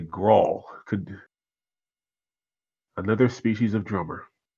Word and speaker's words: Grohl: Is that how you pronounce that? Grohl? Gull? – Grohl.